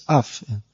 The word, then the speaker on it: enough: You can't, just like if you see C-O-U-G-H, cough, how can you say, how do you know it's pronounced cough, and then E-N-O-U-G-H, enough. So it's not cough, it's not off, it's uff. The O-U-G-H becomes uff, and then S-L-O-U-G-H becomes slough.